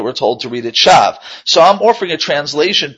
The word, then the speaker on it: shav: So therefore, the word shav, to lie, was really pronounced shaw, and shoah, which means destroyed, are really very similar in sound.